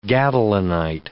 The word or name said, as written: gadolinite